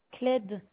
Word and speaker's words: Clede: Cled. – Cled. – Oui. – Comment ça s'écrit, s'il vous plaît? – C-L-E-D-E. – C-L-E-D-E. – C-L-E-D-E. – C-L-E-D-E. – Oui, c'est... C-L-E-D-E.